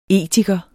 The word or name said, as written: etiker